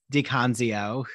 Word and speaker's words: DiConzio: So I'm sure we'll cover all of that with William DiConzio, who didn't even ask him if I pronounced that right, but I'm going with the Italian pronunciation. Okay, he gave me a thumbs up.